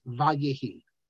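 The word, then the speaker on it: Vayehi (וַיְהִי): If you look on page 9, and if you look again on page 18, he tells you that you pronounce this Vayehi.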